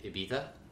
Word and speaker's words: Ibiza: So, I pronounced that right. – What was that? – Everybody at home wondering if I pronounced that right. I did. – You know what else there is going on in that word, though? I feel like people say it instead of Ibiza, they say Ibiza.